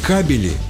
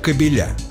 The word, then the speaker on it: кабели: Но словари с этим не согласны. Если речь идет о проводах в защитной оболочке, правильно говорить «кабели». Например, электрические кабели, интернет-кабели, силовые кабели. Ударение остается на первом слоге и в единственном, и во множественном числе.